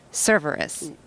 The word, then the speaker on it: Cerveris: Michael Cerveris.